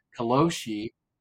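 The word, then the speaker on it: karoshi: It's called koloshi learned to pronounce it correctly. Kaloshi means death from overwork, and people in their 40s are dropping dead simply because they work from 7 to 11.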